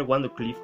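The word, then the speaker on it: Wardenclyffe: La Torre Wandercliffe, o la Torre wondercliff como, se le, como le gusta pronunciarlo,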